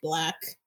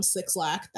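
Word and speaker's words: 6lack: which is six, the number six, and then Lack, but it's pronounced Black. Don't call him Six Lack, that's not his name.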